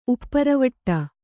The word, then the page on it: ಉಪ್ಪರವಟ್ಟ: upparavaṭṭa